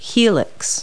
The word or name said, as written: helix